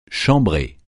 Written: chambrée

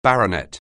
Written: baronet

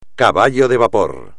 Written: caballo de vapor

